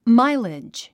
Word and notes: mileage: mǽilidʒ; マイレッジ

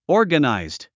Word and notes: organized: ɔ’ː r gənàizd; オーガナイズド

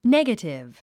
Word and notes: negative: négətiv; ネガティブ

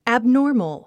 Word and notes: abnormal: æbnɔ’ː r məl; アブノーマル

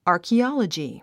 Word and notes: archaeology: ὰːrkiάlədʒi; アァキオロジィ

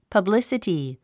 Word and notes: publicity: pʌblísəti; パブリスィティ

